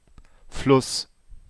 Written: Fluss

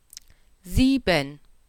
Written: sieben

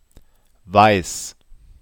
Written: weiss

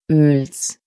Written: Oels

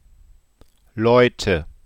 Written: Leute

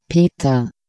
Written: Peter

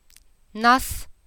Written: nass

